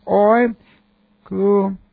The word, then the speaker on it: oikoumene: So here's our word, oikoumene.